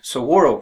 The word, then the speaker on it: saguaro: Now let's take, for example, the saguaro cactus. That's spelled S-A-G-U-A-R-O, cactus.